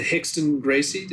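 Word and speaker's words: Rickson Gracie: as opposed to Hickson Gracie.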